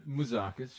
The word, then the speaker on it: Mouzakis: And I was like, it's, you know, Mouzakis.